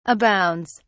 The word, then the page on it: abounds: British English